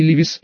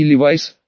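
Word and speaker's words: Levi's: И Левис, и Левайс, что ближе к оригиналу, и Леви Страус, и Леви Стросс, по имени отца-основателя компании, которого правильнее было бы именовать Левай Страус.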